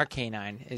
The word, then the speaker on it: Arcanine: Arcanine is how you say it because it's a. – Oh, Arcanine. – Arcane, and it's a canine.